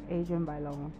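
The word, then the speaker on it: Adrienne Bailon: Adrian Ballone. I guess I was just so sure. So let's start again. Tamar Braxton, Adrian Ballone, Lonnie Love, Tamara Mari Housley, and Jeannie Mai.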